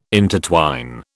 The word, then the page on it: intertwine: British English